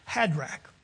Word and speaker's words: Hadrach: The burden of the word of the Lord in the land of Hadrach, just like Shadrach, Meshach, and Abednego, pronouncing this, Hadrach.